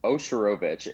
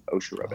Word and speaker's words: Ozhurovich: It's Ozhurovich. It's actually pretty simple, but people see it, they get super intimidated. So even syllables, Ozhurovich.